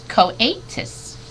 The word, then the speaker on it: coatis: These are coatis, Miss Brisbane said, reading from the letter, pronounced coatis.